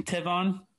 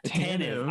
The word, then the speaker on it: Tanev: Brandon Tavon or Tanev was in full practice. – Tanev.